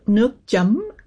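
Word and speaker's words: nước chấm: How do you pronounce it? Nukjum? – Nukjum. – Nukjum. Okay. – Yeah, there you go. – I'm trying. I've also heard nukcham, so I don't – nukjum. – Yeah. Jum. So nook means water or liquid, and jum means dip or dipping.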